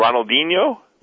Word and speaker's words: Ronaldinho: Oh, Ronaldinho.